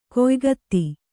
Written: ಗಾಯಕತೆ

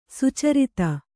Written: ಸಚ್ಚರಿತೆ

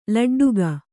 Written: ಲಡ್ಡಿಗೆ

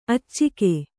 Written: ಅಚ್ಚುಗ

